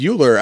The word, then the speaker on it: Euler: So last episode we were talking about the puzzles and everything and Project Euler came up and then someone had to correct us and say that it's pronounced Euler. So it's Project Euler.